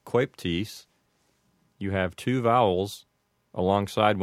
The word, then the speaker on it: coeptis: So in this case, the O and the E go together and sound like oi. So as far as my knowledge goes, this expression would be pronounced anuit coeptis.